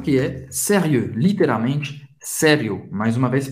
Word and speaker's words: sérieux: Sérieux. Sérieux. O X não se pronuncia. E o em francês tem o som do E bem fechado. Faz a boca do O e fala E. E. Sérieux.